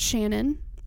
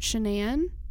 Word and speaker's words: Shanann: It was originally pronounced Shannon, and she was married before she married Chris. And after that divorce, she kind of started just pronouncing it Shanann. And like everybody now pronounces it Shanann, even Chris and her friend Nicole. But her parents still say Shannon, so it's confusing.